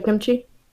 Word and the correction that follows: Akimichi: Akimichi. – Akimichi. Yeah. Um, I missed a syllable.